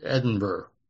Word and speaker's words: Edinburgh: It's spelt with a G-H on the end, but I never know what people say. – It sounds like what is under a saddle, Edinburgh.